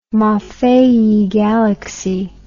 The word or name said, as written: maffei galaxy